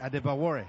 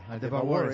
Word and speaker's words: Adebawore: Sí, que regala 5 yardas a DeBoer. ¿Lo dije bien? – Adebawore. – Adebawore, sí.